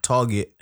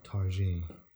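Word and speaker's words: Targett: Like, Grealish and Target were killing him. – I think it's pronounced Tarji. – No. Yeah. No. No, no. Yeah, it's definitely not Tarji.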